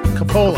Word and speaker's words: Coppola: Coppola? How do you say it? Coppola. Coppola. Coppola. – There we go. Yeah, like cola with a cola.